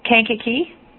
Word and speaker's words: Kankakee: Kankakee.